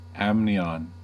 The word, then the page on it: amnion: American English